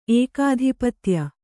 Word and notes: ಏಕಾಧಿಪತ್ಯ: ēkādhipatya

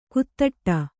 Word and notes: ಕುತ್ತಟ್ಟ: kuttaṭṭa

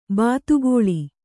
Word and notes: ಬಾತುಗಾಲು: bātugālu

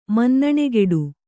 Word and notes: ಮನ್ನಣೆಗೆಡು: kannada

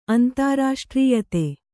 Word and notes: ಅಂತಾರಾಷ್ಟ್ರೀಯತೆ: antārāṣṭrīyate; Kannada

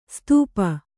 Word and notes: ಸುತಪ: sutapa

